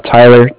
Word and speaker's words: Tyler: Tyler, T-Y-L-E-R, and calling with Cardiff. It's spelled C-A-R-D-I-F-F, as in Frank.